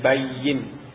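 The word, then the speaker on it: bayyin (بَيِّنْ): Jadi di sini membacanya bayyin dengan mentasdid ya-nya. Jadi tasdid itu kadang-kadang ditulis, kadang-kadang tidak. Seperti di sini ini tidak ditulis, tapi mafhum. Mafhum ini dibaca dengan bayyin, bukan bayna.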